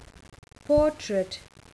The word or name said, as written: portrait